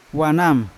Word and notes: wanam: Yawa

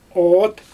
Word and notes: oot: Xyzyl

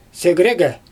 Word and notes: segrEgE: Xyzyl

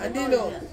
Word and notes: andino: Ansus

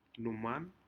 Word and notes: numan: Aneityum

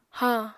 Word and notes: hañ: apatani

